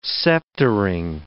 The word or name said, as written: sceptering